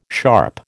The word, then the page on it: sharp: American English